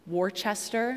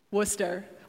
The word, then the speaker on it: Worcester: And in Massachusetts, there is a town that if you were to read the name of the town, you would swear that the town should be called Worcester. You guys know this? You guys have been to New England? How do you pronounce it? Worcester, or Worcester, if you have a little accent. You read it, and you're like, oh, this town should be called Worcester. But no, it's Worcester. And if someone says it any other way, you're like, oh, you're not from here. So anyway, that's what happens.